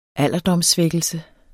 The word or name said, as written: alderdomssvækkelse